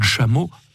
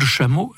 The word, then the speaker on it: Chamot: Oui, en 1927, Claude Chameau, son nom s'écrit C-H-A-M-O-T, Claude Chameau vit à Cherbourg, où il est ingénieur agronome, alors que sa charmante épouse tient un petit négoce de chaussures.